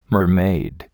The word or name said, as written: mermaid